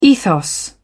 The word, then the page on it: ethos: British English